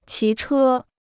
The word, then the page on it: 骑车: qí chē